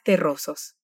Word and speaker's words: terroso: La R de inicio de palabra en español tiene una pronunciación vibrante, r, rojiza, igual que la palabra terroso, que tiene doble R en el medio. En ambos casos el sonido es vibrante.